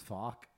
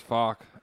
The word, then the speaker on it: Focke: His name's Fock. – Yeah, his name's Fock. F-O-C-K-E. Fock.